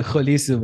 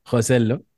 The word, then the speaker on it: خوسيلو: في يوزر شاب علي يقول انطق اسم صح اول بعدين تعال حلل، فاني قلت خوليسو بدل خوسيلو.